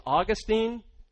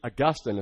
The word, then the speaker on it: Augustine: And his name looks like it's Augustine, but it's really Augustine is how you pronounce it.